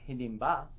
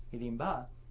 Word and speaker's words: Hidimba: So at a certain point, they're in the forest, they're wandering, and they're all sleeping, and this is this Rakshasa named Hidimba, that's a short A, not the long A that we have there, Hidimba, smells them, and he says, oh, you know, because these guys eat blood, right?